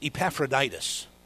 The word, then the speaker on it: Epaphroditus: But his name is Epaphroditus.